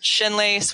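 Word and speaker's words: Scinnlaece: One's my personal account, and it's at Shinlace, which is spelled SCINN.LAECE.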